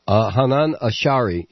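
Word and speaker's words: Hanan Ashrawi: I had the right pronunciation, though, right? – Hanan Ashari, yes. – All right, okay, it's a little bit different.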